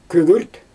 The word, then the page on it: kügürt: Xyzyl